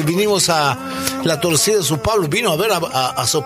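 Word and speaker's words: São Paulo: Dice Sao Paulo, ¿está bien dicho así? – Pues perfecto.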